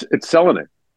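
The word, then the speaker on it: Selänne: What's interesting is that had he come over later, we've said Teemu Selanne's name wrong for 30 years. It's Selanne. – Is it? – Yeah. In Finnish, the first syllable is always accented.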